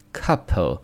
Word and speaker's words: couple: 那么这个单词呢，注意结尾的 e 是不发音的啊，后面两个音呢是 p 和后面这个辅音组成的 p。 po po 这么去读，couple couple。